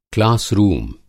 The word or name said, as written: क्लास रूम